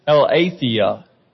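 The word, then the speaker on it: aletheia (ἀλήθεια): The Greek word that we've got here is aletheia. Aletheia. Looks like aletheia, but it's got a long A sound.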